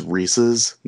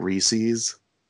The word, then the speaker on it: Reese's: It's funny, we both mentioned Reese's, and I'm going to take this time to say it's Reese's, not Reese's, because I hate when people say Reese's. It drives me insane. – It's really funny because sometimes I overthink it. I'm like, oh, wait, am I saying it right? I know what's right, but then when I think about everybody hating when it's said wrong, then I'm like, am I gonna say it right? – Yeah, Reese's drives me nuts because like it's a dude's name. His last name is Reese that created it.